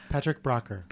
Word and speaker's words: Patrick Braucher: I forgot to say, is that the correct pronunciation? Braucher? Yes. Oh, and I got the wrong mic for you, so let's say it one more time. – Patrick Braucher.